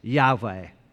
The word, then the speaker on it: Yahweh: Most biblical scholars believe that this is probably, when it's put together, pronounced Yahweh. We don't know for sure.